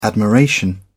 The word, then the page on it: admiration: British English